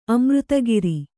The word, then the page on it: ಅಮೃತಾಕಾರ: Kannada